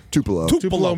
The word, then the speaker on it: Tupelo: East Tulipo. How do you say that in Mississippi? – Tupelo, Mississippi.